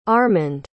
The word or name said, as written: armond